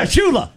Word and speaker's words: Shula: It rhymes with moolah. – Yeah, Shula.